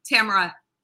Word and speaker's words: Tamara: So, Ron, on the West Coast, it's Tamara. In the Midwest here, it's Tamara. And on the East Coast, it's Tamara. So in Brooklyn, it's Tammy.